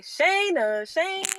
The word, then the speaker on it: Shana Shayne: People say Shana Shayne. That's how you say my name.